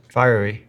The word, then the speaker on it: fiery: Fury. Fury. How do you say that word? Fiery. Oh, yeah. Fiery.